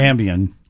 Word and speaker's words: Ambien: How do you say it? Ambien? – Ambien.